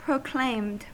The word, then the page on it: proclaimed: American English